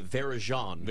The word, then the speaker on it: Varejão: He's diligently typing, but the former play-by-play voice of the Wizards, who made sure he pronounced Anderson Varejão's name the true Brazilian way, Varejão.